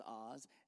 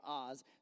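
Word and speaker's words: Az: His name is Oz. A-Z. His name is not Oz. Well, it's spelled A-Z. I know that don't make any sense. But that's his name, Oz.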